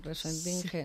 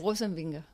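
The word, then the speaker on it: Rosenvinge: No, lo decimos como si fuera castellano, Rosenbinge. Sí. No es así como se dice en danés. – Dinos, en danés. – Rosenbinge.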